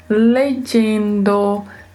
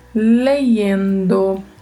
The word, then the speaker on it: leyendo: Pode se pronunciar legendo, legendo, leyendo, lexendo. Também depende das regiões.